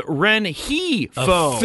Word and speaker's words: Renjifo: Again, how much of the G are we supposed to pronounce? – It's... an H. – You say not at all. – It's an H. Renhifo.